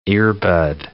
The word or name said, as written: earbud